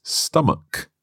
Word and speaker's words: stomach: Well, this word ends with ch, and that's normally pronounced ch, like church or chicken. But here it's stomach, a hard k sound.